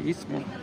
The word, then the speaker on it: Istmo: Istmo, que luego lo digo mal, y IST, Istmo, en el Istmo de Tehuantepec, eh, es la obra más importante económicamente.